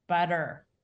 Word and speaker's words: butter: If I say, "You want some butter," that identifies you as an American speaker because it's called a flap. That T that's pronounced like a D between a stressed and unstressed syllable is something that only developed in the United States after most of the colonists had settled here. So British speakers either glottalize, so butta, or they pronounce the T, butta. But an American says butter.